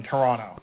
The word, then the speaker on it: Toronto: There's barely one T in Toronto.